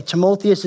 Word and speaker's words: Timotheus: Timotheus is how you pronounce it, but he's called a true son of the faith.